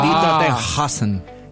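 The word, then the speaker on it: Die Toten Hosen: En realidad se pronuncia. Yo estudié alemán, chicos. Ustedes saben. Die Tottenhosen. Die Tottenhosen.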